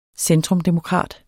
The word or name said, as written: centrumdemokrat